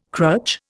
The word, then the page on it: crutch: Indian